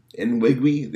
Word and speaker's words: Nwigwe: So Toby, I cannot pronounce the man's name. Toby Nwigwe? Nwigwe? There we go.